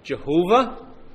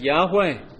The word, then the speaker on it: Yahweh: Now, some people pronounce the original Hebrew word Jehovah, but it's more likely that it should be pronounced something like Yahweh.